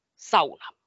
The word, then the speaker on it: sauna: Yes, everybody pronounces it wrong. It's sauna.